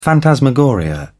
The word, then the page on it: phantasmagoria: British English